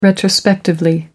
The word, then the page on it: retrospectively: American English